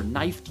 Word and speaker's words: knife: For instance, if a knight knocked your knuckle or knifed your knee, why would there be so many K's? Well, the K's are silent. By mistake. The original Old English forms of these words were pronounced with K sounds.